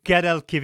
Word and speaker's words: Guadalquivir: Guadalquivir. – Yeah. Oh, no, I'm not even pronouncing it correctly. Do you want to try it one more time for me? – The Guadalquivir.